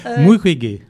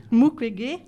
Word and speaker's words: Mukwege: Comment tu le prononces? Alors, Mukwege. – Mukwege, voilà.